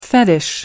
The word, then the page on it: fetish: American English